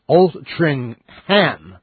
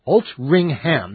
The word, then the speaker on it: Altrincham: And Altrincham is pronounced Altringham. Altringham. That's how you're supposed to... It's spelled Altrincham, all right? But it's pronounced Altringham. Fine.